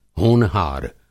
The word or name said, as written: होनहार